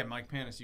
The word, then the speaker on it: Panus: And got somebody really interesting here with me, my boy Mike Panus. I'm saying that right, yeah? – Yeah, Mike Panus, you got it, brother.